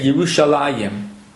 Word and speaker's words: Yerushalayim: When we pronounce Yerushalayim, we say Yerushalayim.